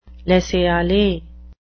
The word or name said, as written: laissez-aller